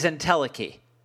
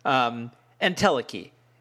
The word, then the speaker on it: Entelechy: The actual pronunciation of her album is Entelechy. That's the correct pronunciation. I was trying to get all fancy and Greek sounding and, you know, I wanted to impress everybody. And I made an idiot of myself by mispronouncing her album throughout the entire show. Entelechy is how you do it.